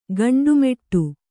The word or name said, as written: ಗಂಡುಮೆಟ್ಟು